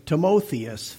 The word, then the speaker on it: Timotheus: And the name, the Greek pronunciation was Timotheus, Theos, God.